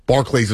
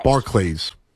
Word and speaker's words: Barclays: It's Barclays. Not like Barclays. Like Charles. – Barclays. – Like Charles. – Barclays.